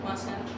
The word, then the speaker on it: Wasatch: Wasatch? – Yeah, Wasatch. – Rhymes with Sasquatch? – Sort of, yeah.